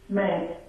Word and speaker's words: Smeenk: And it is Luz, L-U-Z, Smenk, S-M-E-E-N-K.